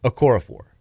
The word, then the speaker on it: Okorafor: You just need an extra syllable. Okorafor.